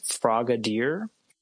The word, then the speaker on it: Frogadier: And then from the musher side, it is, and I'm going to butcher this one, it's frogadier. – Yeah, I think that's exactly how you would say it.